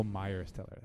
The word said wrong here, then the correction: Miles Teller: That's Miles Teller. I just said it wrong.